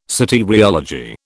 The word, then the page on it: soteriology: British English